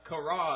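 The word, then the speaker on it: qara: And the Hebrew word is kara, and that's how I'm going to pronounce it. That's Q-A-R-A.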